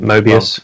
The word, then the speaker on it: Mobius: Mobius.